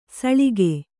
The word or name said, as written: ಸೌಳಗೆ